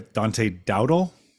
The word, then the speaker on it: Dante Dowdle: I mean, I don't know how to Dante Dowdle. Is that how you say his name? – Yeah. Yeah.